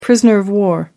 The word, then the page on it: prisoner of war: American English